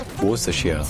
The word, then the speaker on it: Worcestershire: En fait, c'est un mot en seulement trois syllabes qui se dit Worcestershire. Worcestershire. Worcestershire.